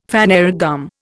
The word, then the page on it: phanerogam: Indian